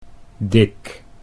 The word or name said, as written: Dick